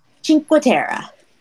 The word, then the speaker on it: Cinque Terre: Cinque Terre.